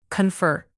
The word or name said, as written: confer